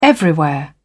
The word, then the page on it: everywhere: British English